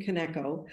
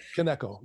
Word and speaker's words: Kaneko: That's, and I'm not sure how to pronounce Kaneko, is it? – It is. June Kaneko. – Kaneko, yes.